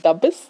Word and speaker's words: Dabbis: Jemand, der dabbisch ist, ist ein Dabbis. – Ja, genau. – War das richtig betont? Dabbis? – Dabbis? – Muss man es tief machen? Tief auf der zweiten Silbe?